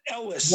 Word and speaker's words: Elwes: I don't know how you say his name. – Elwes. – What's that? Elwes? – Elwes.